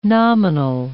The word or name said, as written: nominal